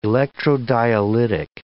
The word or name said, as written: electrodialytic